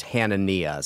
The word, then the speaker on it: Hanania: We mentioned, how do you say his name? Hanania? – I had a friend with the same last name and it was pronounced Hanania. So that's what's Hanania.